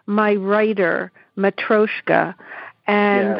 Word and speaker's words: Matryoshka: You know, I shared the introduction with folks, but you know, one thing I think is really amazing is that the Matryoshka figure, am I saying that right? – Yes.